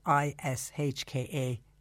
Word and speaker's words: Ishka: I love the name of that company, the way they spell it I S H K A. It's literal Ishka.